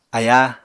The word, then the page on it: aya: apatani